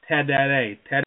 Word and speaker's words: tereré: It's kind of difficult to pronounce, but it's called tereré.